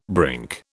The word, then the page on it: brink: British English